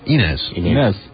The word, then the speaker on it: Inez: I don't know, how do you pronounce that? Inez? – Inez. – Inez. Okay.